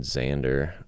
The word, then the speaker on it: Xander: His last name is spelled X-A-N-D-E-R, Nathan Zander.